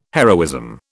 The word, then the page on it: heroism: British English